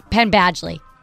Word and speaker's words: Penn Badgley: Penn Badgley.